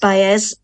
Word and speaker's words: Baez: It's Joan Baez. Now, I think it's pronounced Baez. I don't think it's Joan Baez.